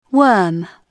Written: WORM

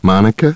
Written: Monica

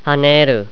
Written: haneru

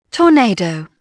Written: torn

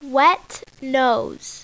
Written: wet nose

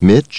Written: Mitch

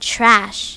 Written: trash

